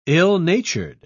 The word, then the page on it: ill-natured: British English